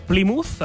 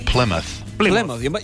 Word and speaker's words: Plymouth: Plymouth. – Jo hagués dit Plymouth, perquè és una Y, però no és Plymouth. – És que enganya, per això. – Plymouth